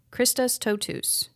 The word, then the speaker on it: Christus totus: Christus totus. Did I say that right? – Yeah.